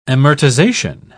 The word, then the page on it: amortisation: British English